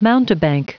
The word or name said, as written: mountebank